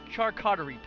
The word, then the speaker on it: charcuterie: So, I mean, mixed olives, fruits and cheeses, and the it's called the charcuterie. – Cutlery plate, like, I can never pronounce that word. But you're right, it's meats and cheeses on like a wooden platter, I believe. – Absolutely, it's charcuterie plate.